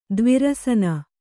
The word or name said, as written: ದ್ವಿರಸನ